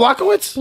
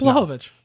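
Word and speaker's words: Błachowicz: So my Verdum notes And my the majority of my Blahovic show is a blow. – Yeah, you got it. – Blachowicz. – It's one of the two. – It's not Blachowicz? – Blahovic. – No. Oh, Blahovic. Yeah.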